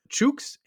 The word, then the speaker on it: chooks: when we were talking about the world's ugliest lawn, I used the word chooks and pronounced it to rhyme with the word dukes. He corrected me, letting him know that he's from that area, that it actually rhymes with books. So it should be chooks, not chooks.